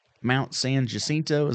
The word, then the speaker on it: Mount San Jacinto: Well, tell me about going to Mount San Jacinto.